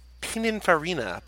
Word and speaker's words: Pininfarina: It's a Pininfarina Batista. – Pininfarini? – Is that how it's pronounced? – Is it Pininfarini's making their own cars now? – It's this. – It's Pininfarini. – Pininfarina. P-I-N-I-N-F-A-R-I-N-A. – Yeah, Pininfarini.